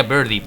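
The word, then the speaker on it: Birdie: El personaje de Birdy, que bueno, se pronunciaría Birdy, pajarillo, en español la traducción sería pajarillo.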